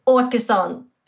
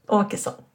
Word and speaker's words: Åkeson: Say it correctly, just so everybody hears it correctly. – Okay, so it's Åkeson. It's Swedish. – Åkeson. – Åkeson, yeah.